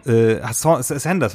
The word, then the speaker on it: Saunders: Sanders wird ausgesprochen. – Ich glaube, ja. – Jetzt, wo du es sagst, kommt mir das auch so bekannt vor, dass das dass Saunders falsch ist.